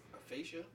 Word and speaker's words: aphasia: How you pronounce that shit? – Hyperglycemic. – Right, that. Aphasia? – Yeah, there you go. Sure. – Cool. Aphasia? Yeah, what is that? – A-P-H-A-S-I-A.